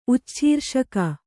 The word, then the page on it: ಉಚ್ಛೀರ್ಷಕ: ucchīrṣaka